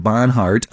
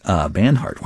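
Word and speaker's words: Banhart: 665 Devendra Barnhart. Barnhart.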